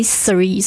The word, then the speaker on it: Ceres: So it's C-R-E-S. – Yes. – Because I never seen this name before. – Yeah. A lot of people, when they saw my name, they pronounce it Ceres, but it's actually Ceres.